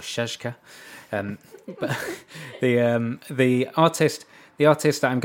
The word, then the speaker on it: Czaszka: It's from a record label called Shazka Records.